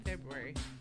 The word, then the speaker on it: February: February. – Wait, say that again. – February, right? I knew it. I was right. Everyone goes, no, it's a silent R.